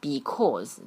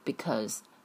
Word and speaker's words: because: Carol 这个美化 add 音要拉到位了上下嘴巴然后是 dog 接下来是 howling 最后是 because 很多同学这个 because 他发的是 because 这都是错的啊我是模仿错误的发音正确的是 because